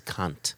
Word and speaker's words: Kant: The, the, philosopher's name is Kant. – Actually, exactly. – rhymes with aunt.